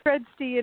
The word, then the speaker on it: Fredsti: It's Fresti, and it's close enough.